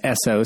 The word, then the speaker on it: Essoe: But my name is spelled Jordan, like the country, and last name is Esso, spelled E-S-S-O-E, like the gas conglomerate, but with an extra E for extra.